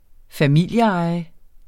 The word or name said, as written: familieeje